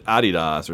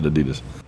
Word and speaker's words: Adidas: And I think they pronounce it Adidas or something, but we pronounce it Adidas.